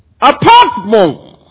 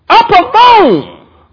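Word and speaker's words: apartmon (ἁρπαγμόν): The noun is apartmon, apartmon. Syllable is on the last. The accent is on the last syllable.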